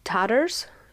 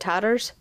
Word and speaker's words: Tatars: Tatars is what they said. Tatars. Almost like taters, but Tatars.